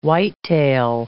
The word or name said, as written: whitetail